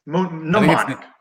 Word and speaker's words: mnemonic: Mnemonic, right? – I think it's mnemonic. Yeah, I think the M is silent in mnemonic there.